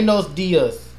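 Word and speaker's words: Buenos días: Buenos dias.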